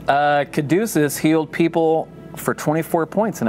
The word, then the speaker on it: Caduceus: Caduceus.